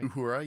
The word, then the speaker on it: Uhura: Lieutenant Uhura, am I saying that right? – Uhura, yeah.